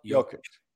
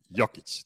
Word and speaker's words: Jokic: Nikola Jokic. – Excellent. Jokic.